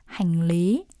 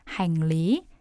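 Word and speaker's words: hành lý: 네, 이건 한자어 기원이에요. 중국어로 짐을 싱리라고 하거든요. 그래서 그거랑 발음 틀린 거 아니야? 네, 중국어 끊은 지가 오래돼가지고 네, 발음이 비슷해요. 싱리 이게 중국어고요. 베트남어로는 행리. – 네, 행리. – 라고 합니다.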